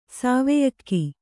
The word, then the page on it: ಸಾವೆಯಕ್ಕಿ: sāveyakki